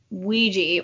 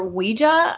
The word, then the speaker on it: Ouija: and it came back with Ouija or Ouija. I hear people pronounce it both ways. – Spelled O-U-I-J-A.